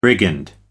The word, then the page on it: brigand: American English